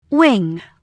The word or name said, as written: wing